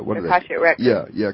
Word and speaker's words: Akashic Records: As we all know, we've heard of what they call the Akash, Akashic Records. – Yeah, Akashic Records. – Right. Uh-huh. – I can never pronounce that. Someone pronounce it loudly, please. – Akashic Records, yeah. – Akashic Records, okay.